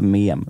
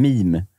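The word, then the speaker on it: meme: Det var ju någon som var på mig för att jag inte kunde uttala mem. Vad är det? Mem? Ja, vad säger man? Mim. – Ja, exakt. Ja.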